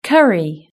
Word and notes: curry: British English

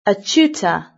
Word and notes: a tutor: British English

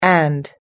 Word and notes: and: American English

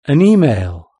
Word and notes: an email: British English

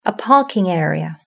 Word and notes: a parking area: British English

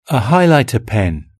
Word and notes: a highlighter pen: British English

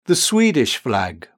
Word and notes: the Swedish flag: British English

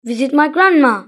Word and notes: visit my grandma: British English

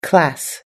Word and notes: class: American English

